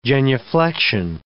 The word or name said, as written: genuflection